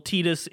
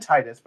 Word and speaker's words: Tidus: Well, Tidus is in Destiny Islands, so. – Yeah. – Yes. – His name is both pronounced Tidus and Tidus by the same character in Kingdom Hearts 1 and 2.